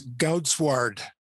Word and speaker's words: Goudswaard: Yes, Goudswaard. It's Dutch.